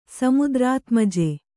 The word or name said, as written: ಸಮುದ್ರಾತ್ಮಜೆ